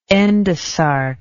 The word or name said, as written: endosarc